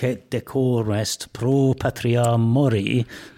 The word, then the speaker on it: mori: Well, I think he would have said Dulce et decorum est pro patria mori. And mori has to rhyme with glory, of course.